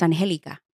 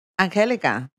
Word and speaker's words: Anjelica: Anjelica, with a yes. J. It's, uh, as my mom would say it, I've been mispronouncing it my entire life. It's Anjelica. Anjelica.